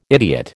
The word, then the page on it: idiot: American English